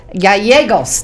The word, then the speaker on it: Gallegos: I have with me today Steve, I'm going to try this, Gallegos.